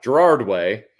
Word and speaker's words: Gerard Way: Gerald Way Gerald Gerard Gerard Way mm.